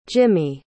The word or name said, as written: jimi